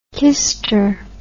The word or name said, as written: kister